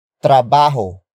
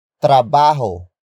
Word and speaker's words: trabaho: It is the focus marker that introduces the main topic of this sentence, and that happens to be trabajo. Trabajo. Trabajo. Trabajo is spelled as T-R-A-B-A-H-O.